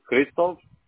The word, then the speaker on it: Hristov: Middle name is Christoph. It's spelled H-R-I-S-T-O-V.